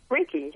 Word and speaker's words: Reiki: Reiki, yes.